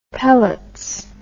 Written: pellets

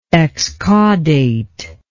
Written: excaudate

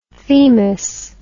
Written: themis

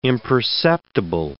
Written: imperceptible

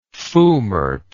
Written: foulmart

